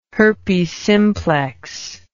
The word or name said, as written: herpes simplex